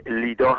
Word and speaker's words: لد: به هر حال دکتر جورج حبش باید عرض بکنم که در 1926 در شهر لد که لیدا هم تلفظ میشه، نزدیک تل اویو امروزی هست، متولد شد و دوران تحصیل خودش رو در فلسطین گذراند.